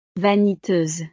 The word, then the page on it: vaniteuse: frances